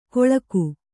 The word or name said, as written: ಕೊಳಕು